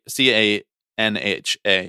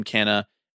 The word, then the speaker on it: Canha: So they choose to pronounce it Canha, but if they were going to pronounce it the traditional way that you would say that letter combination, that consonant combination in Portuguese, it would be Canha.